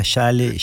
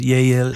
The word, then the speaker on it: Yale: Qué maravilloso que lo digas y que encima lo, estuve estudiando para cómo se pronuncia Yale en la universidad. Vos sos profesora de la universidad. De, yo decía Yale, Yale, acá un desastre. Pero es Yale, algo así.